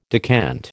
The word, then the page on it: decant: American English